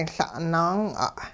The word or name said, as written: La'-naang'a'